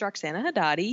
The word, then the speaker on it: Roxana Hadadi: My name is Roxana Hadadi.